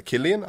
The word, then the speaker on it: Cillian: Og så har du Cillian Murphy, eller Killian, alt etter hvordan du velger å uttale det.